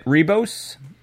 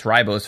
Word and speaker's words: Ribos: The Doctor and Ramana trace the first segment to the planet Rebos, which I pronounced Ribos for several years leading up to the viewing of this episode, where they discover 2 grifters who are using it to try and swindle an exiled tyrant.